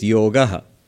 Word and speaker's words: yoga: But the correct pronunciation would be yoga. Not a. Yoga. In Hindi, you'd say yog. In Bengali, jog. But never yoga.